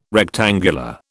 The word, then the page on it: rectangular: British English